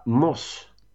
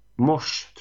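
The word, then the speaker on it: Moś: Den hamnade, blev på en delad tredje plats och istället blev det en ballad med Kasia Moss. Mosh tror jag den uttalas. Eh, Flashlight heter låten och det kändes väl inte så Nej, den, hörde jag, den hörde jag också.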